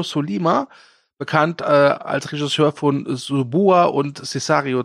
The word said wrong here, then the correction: Sicario: 2 oder Sicario, wie immer man es aussprechen mag. Sicario. Sicario, ja.